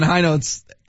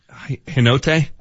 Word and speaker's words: Hinote: Is he the one that Vic pronounced Hinote? – I think he and Gary Miller have the epic story of they like, Dan Hinote. – Right. – It's like, no, it's Hynote.